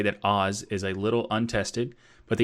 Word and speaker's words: AHS: But in parentheses, they say it's pronounced Oz, so O-Z, Oz.